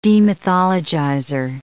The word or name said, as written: demythologizer